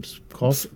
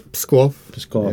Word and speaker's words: Pskov: Pskov. – Pskov, yes.